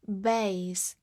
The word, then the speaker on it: base: base，b a s e，base，字母 a 发它本身的音 a，末尾的 e 不发音。 base，它是一个名词，表示基底、基础或者基地。